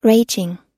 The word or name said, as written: raging